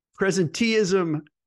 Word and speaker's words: Presenteeism: Presenteeism and Resenteeism. I pronounced that right, right, Chris? – Yep, perfectly.